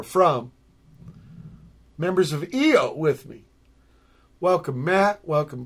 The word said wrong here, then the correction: Io: It's actually pronounced I-O.